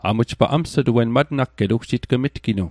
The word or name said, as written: matnagget